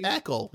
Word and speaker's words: Eckle: So it's an Eckle with some C? – It's Eckle, yes. – Okay, like Freckle, but with no fra. – Like Freckle without the fra, yes.